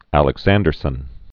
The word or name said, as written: Ernst Frederick Werner Alexanderson